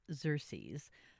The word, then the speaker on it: Xerces: And today I am joined by Matthew Shepherd, a communications director for Xerces. Did I say it right, Matthew? – You did, yeah.